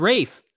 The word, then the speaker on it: Ralph: Why do they pronounce it Rafe when it's actually Ralph? Which is it's not. It's Rafe. – Yeah. – Yeah. – It's spelled Ralph.